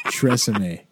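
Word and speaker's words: TRESemmé: I'm just going to judge your pronunciation. It's called Tresemme.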